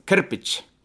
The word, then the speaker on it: Krupic: Vi har ju ett namn av samma, Amil Krupic. – Ja. – Är jag rätt på det? – Ja, Krupic.